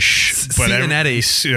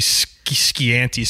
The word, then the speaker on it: Scianitti: Which is like, the reason behind that is so stupid, because I phonetically spelt out my name, because Italians, especially when the SCI in Italian is Scianitti. – Everyone gives me Scianti, Scianitti, and Cincinnati. Wow. But I phonetically spelt it out Schnettee, which would be Schnetti,